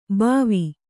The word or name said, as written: ಭವಿ